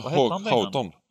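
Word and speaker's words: Hughton: Kan du uttala hans namn eller? Jag vet inte fan, det är svårt det där. Hackton, Bob Hackton, vad säger man? – Chris Houghton. – Houghton.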